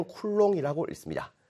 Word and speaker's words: 쿨롱: 자, 그리고 이런 전기 값을 우리가 하나의 단위로 나타낼 건데, 이 단위를 우리는 시라고 쓰고, 읽을 때는 쿨롱이라고 읽습니다. 쿨롱.